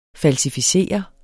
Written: falsificere

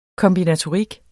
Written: kombinatorik

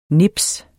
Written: nipset

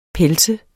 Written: pels

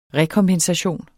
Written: REKOMPENSATION

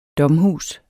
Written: domhus

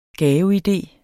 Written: GAVEIDEER

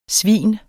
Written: svin

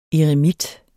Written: Eremits

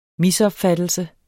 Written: misopfattelse

